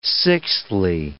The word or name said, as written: sixthly